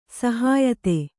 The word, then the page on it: ಸಹ್ಯತೆ: shyate